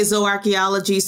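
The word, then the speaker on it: Zooarchaeology: So there are several different pronunciations. Okay. I say Zoarchaeology. Some people say Zoo. Mm-hmm. It really depends on who trained you.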